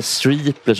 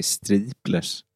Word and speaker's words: Streeplers: Men det är väl Streeplers och Flamingo-kvintettens arvingar, va? – Är det inte viktigt med Streeplers att man egentligen säger Streeplers? Alltså, inte Streeplers utan Streeplers, ja. – Ja, inget engelskt uttal.